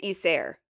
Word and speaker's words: Isère: Isère? – Isère. I think it's Isère. – Isère. – Yeah, because you would say the I like E, and then I think that's how you say it.